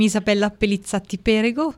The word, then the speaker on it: Isabella Pelizzatti Perego: So I'm Isabella Pellizzate Perego, quite a long surname and complicated, but I'm together with my two brothers, owners of ArPePe.